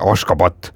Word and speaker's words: Ashgabat: Küll aga valdav osa nendest elab siis pealinnas, mida noh, paljud meist võibolla nagu lihtsustatud korras tunneksid Ashgabadina, aga tegelikult on ta nii kirjapildi mõttes kui ka häälduse mõttes ikkagi Ashgabat.